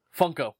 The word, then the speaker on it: Funko: Or however you pronounce it. – Funko.